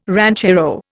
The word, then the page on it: ranchero: Indian